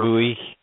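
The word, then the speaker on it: Ogbuehi: So there's, you know, when you throw Humphreys and then the Obichi, how do you say, Ogbui or whatever? – Ogbui, yeah.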